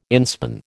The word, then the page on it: inspan: American English